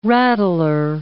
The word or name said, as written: rattler